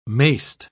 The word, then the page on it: mayst: British English